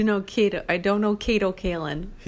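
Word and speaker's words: Kato Kaelin: We have Kate Callan. Is that how you say your name? – It's Kato Callan.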